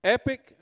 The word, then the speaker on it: epoch: Huh? Epic? No, it's E-P-O-C-H. Still epic? Well, I'll be doggone. They didn't teach us out there where I'm at now. An O sounded like an I. Mark Garrett, you didn't correct me now from first service. You heard me on first service. Thanks, buddy. I'll remember that one. But epic. I thought epic was E-P-I-C.